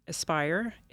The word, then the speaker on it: ESPYR: And the contractor that DOI uses is Aspire. It's spelled ESPYR.